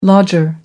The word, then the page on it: lodger: American English